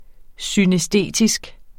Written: synæstetisk

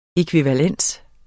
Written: ækvivalens

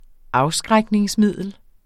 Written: afskrækningsmiddel

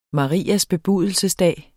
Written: marias

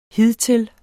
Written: hidtil

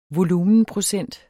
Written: volumenprocent

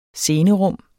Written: scenerum

